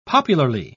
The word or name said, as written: popularly